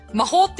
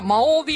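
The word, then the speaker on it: Mahou: で、しかも、私これ、魔法って読んだからね。 – そうそう、スペルがね、日本語で魔法ですからね、完全にね。 – そう。だけど、スペイン語で H は発音しないから、うん、これは魔王ビールなんですね。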